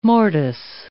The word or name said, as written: mortise